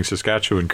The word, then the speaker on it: Saskatchewan: And by the way, kudos for pronouncing Saskatchewan correctly.